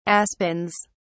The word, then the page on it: aspens: British English